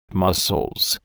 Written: mussels

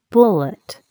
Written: bullet

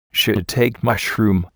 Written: shiitake mushroom

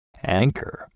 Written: anchor

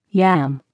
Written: yam

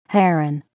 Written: heron

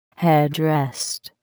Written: headrest

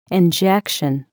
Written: injection

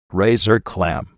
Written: razor clam

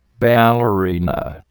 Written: ballerina

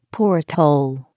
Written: porthole

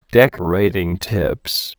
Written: decorating tips

